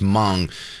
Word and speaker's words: Hmong: And when he says Hmong, I think it's just pronounced Hmong.